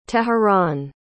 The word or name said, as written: teheran